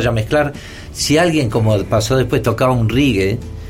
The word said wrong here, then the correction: reggae: Y después, y después, porque Pamela me corrige. Pues, ¿Cómo se dice bien? Reggae. Eh, reggae.